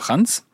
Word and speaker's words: Ханс: Да, как вы правильно сказали, меня зовут Ханс.